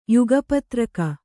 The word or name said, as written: ಯುಗಪತ್ರಕ